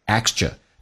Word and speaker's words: extra: 第一个 TR 浊化成 DR 叫 extra, 第二个是 k 是不发音，因为在美式发音两个辅音在一起只用发后面一个，就变成 extra time, 额外的时间。